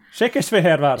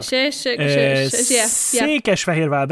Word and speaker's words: Székesfehérvár: Székesfehérvár. – Székesfehérvár, that's correct. However, I have to correct that because the Hungarian Skeptic Society was not founded in Székesfehérvár.